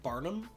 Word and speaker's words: Barnum: Isn't it Barnum? – Is it? B-A-R-N-U-M. So Barnum. Yeah. Yeah. I pronounced it Bardum? Is that what I pronounced it? I don't know what you did. – Yeah. – But it wasn't quite it.